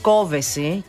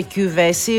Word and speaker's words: Κόβεση: Είναι λέει Κόβεση και όχι ούτε Κιούβεση ούτε Κοβεσή. – Ναι. – Κόβεση λέει είναι. – Παιδιά, η ίδια το είπε. – Κόβεση. – Ναι, σίγουρα τονίζεται στο «ο». Αυτό το θυμάμαι. Αλλά έχω την εντύπωση ότι είπε Κιούβεση. Αλλά εν πάση περιπτώσει, μπορεί να κάνω λάθος. Σε κάθε περίπτωση είναι λάθος το Κοβέση.